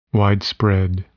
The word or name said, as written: widespread